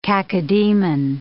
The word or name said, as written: cacodemon